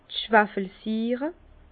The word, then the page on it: d' Schwafelsiire: Bas-Rhin; Herrlisheim